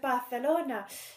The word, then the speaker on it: Barcelona: And, you know, if one has been to Barcelona, which I will keep saying in that accent, as I do think it is important... – That is correct pronunciation.